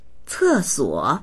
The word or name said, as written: ce4suo3